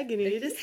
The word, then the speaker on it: Genoïdes: And so you have, you run the Women in Circus Network, and you also have, I don't know if I'm going to pronounce it correctly, but Genoïdes? – Yeah, Genoïdes.